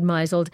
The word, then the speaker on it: misled: Well, Ross Petras, when we first started this segment, we found so many, but I'd already found one, a friend who I said mizzled, he said misled.